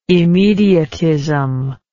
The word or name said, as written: immediatism